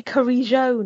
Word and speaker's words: Carrillo: but are we agreed that it's Carrillo, or is it any different to that? – I think it's actually Carrillo now. – Carrillo. – But I've gone with Carrillo because apparently that's okay in Spanish,